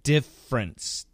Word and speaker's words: difference: Split the difference, S-P-L-I-T, split, which means divide. The difference. Now, some people want to say difference. But most Americans are going to say it in two syllables, difference, D-I-F-F-E-R-E-N-C-E, difference, split the difference.